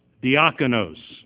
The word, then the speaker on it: diakonos: Another word that's used 30 times is diakonos. Diakonos, and it's spelled D-I-A-K-O-N-O-S, diakonos, and it's 1249 in the Greek, in Strong's, and it means a minister or a servant, a minister or a servant.